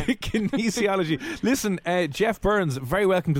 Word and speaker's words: kinesiology: Say kinesiology.